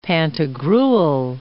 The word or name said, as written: Pantagruel